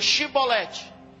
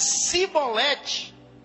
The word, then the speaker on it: Xibolete: Se respondesse que não, diziam, então diga, Xibolete. Diga, Xibolete. Se ele dissesse Cibolete, sem conseguir pronunciar corretamente a palavra, prendiam-no e matavam-no no lugar de passagem do Jordão.